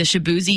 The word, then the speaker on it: Chibueze: It's spelled C-H-I-B-U-E-Z-E. So, in order to not mispronounce it constantly, his football coach wrote it out phonetically on his helmet, S-H-A-B-O-O-Z-E-Y, the Shaboozy you now know today.